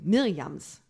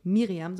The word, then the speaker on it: Mirjams: Und ähm, wenn ihr weitere Informationen zu Mirjams Büchern und Mirjams Werken, Mirjams, nicht Miriam, sondern Mirjams Werken haben möchtet,